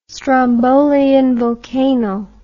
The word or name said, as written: strombolian volcano